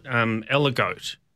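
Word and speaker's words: Aligoté: But you yourself deal with one that's Burgundy's other white, as they call it, Aligote. Unless I've really butchered this, the pronunciation. – Aligote? – Aligote? – Not sure. Aligote? – Aligote. Yep. Well, I say Aligote. Okay. Aligote. – Sounds more French like that.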